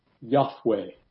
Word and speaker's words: Yahweh: It is, and as best we know, it is pronounced Yahweh.